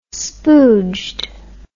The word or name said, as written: spooged